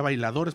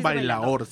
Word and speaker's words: bailaor: Impresionante porque no es el mismo grupo todos los días, todos los días van cambiando a los baila, yo les decía bailadores, pero no se dice bailador es, un bailaor sin la D.